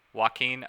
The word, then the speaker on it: Joaquin: Joaquin. Okay, thank you. I don't know why I want to throw an H sound in there. – Remember, you're Joaquin here. – Okay. But Joaquin Phoenix got interviewed about Brother Bear, and the interviewer claimed that it made her cry.